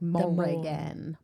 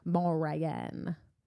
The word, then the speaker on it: Morrigan: Yeah, I love the way they say her name too. It's like they pronounce like every syllable. It's like Morrigan. Morrigan.